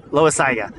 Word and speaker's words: Loaisiga: Loaisiga, did I say that right? Yeah, Loaisiga.